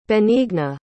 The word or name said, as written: benigna